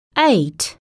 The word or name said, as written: eight